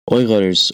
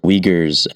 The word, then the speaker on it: Uyghurs: Uyghurs, or Uyghurs as the media often pronounces, are a Muslim ethnic group in northwest China, which is actually occupied territory.